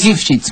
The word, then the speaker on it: Lifshitz: ¿Cómo se pronuncia? Lifshitz. – Lifshitz, Miguel Lifshitz.